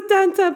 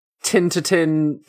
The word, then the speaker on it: Tintin: It is Tintin, Tintin, Tin. – Oh, it's Tintin. Oh. – Sarah always pronounces it like Tan, but yeah. In like Americanized versions, I've always heard it pronounced as Tin, so who the heck knows? – Okay.